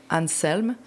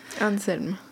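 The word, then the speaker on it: Anselm: Je pense que ni toi ni moi, on peut le prononcer comme il faut, car nous ne parlons pas allemand. Mais je pense que ce que j'ai cru comprendre pendant le film, c'est qu'ils disent Anselme. – Anselme.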